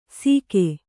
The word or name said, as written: ಸೀಗೆ